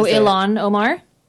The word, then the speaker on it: Ilhan Omar: Oh, Ilhan Omar? – Yes, yes.